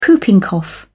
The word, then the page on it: whooping cough: British English